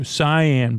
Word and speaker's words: cyan: Jaw set cayenne blue. Cyan blue, though. It's pronounced cyan blue.